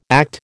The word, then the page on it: ACT: American English